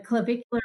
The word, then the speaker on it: clavicular: Is it clavicular or clavicle breathing? – Clavicular. – Oh, I did say it right.